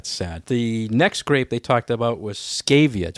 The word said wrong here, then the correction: Schiava: Do I say it right, Kim? Schiava. Schiava schiava.